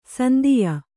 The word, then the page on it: ಸಂದೆಯ: sandeya